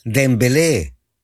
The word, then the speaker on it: Dembélé: Deja ver cómo se pronuncia. No, Dembélé. Hasta se escucha como canción, ¿verdad? Dembélé.